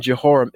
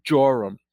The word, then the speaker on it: Jehoram: And it came to pass when Jehoram saw, I'm saying Jehoram, is both pronounced Jehoram or Joram.